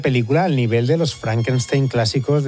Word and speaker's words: Frankenstein: Frankenstein. ¿Me toma el pelo? No, se pronuncia Frankenstein. Usted debe de ser Igor. – No, se pronuncia Igor.